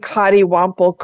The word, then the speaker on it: coddiwomple: Am I saying that right? And what does this mean? – Yeah, you are saying coddiwomple correctly.